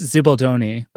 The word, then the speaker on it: Zibaldone: And my gut tells me it's pronounced Zibaldone, but I could be wrong. – You're dead right.